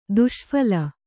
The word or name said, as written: ದುಷ್ಫಲ